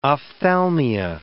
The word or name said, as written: ophthalmia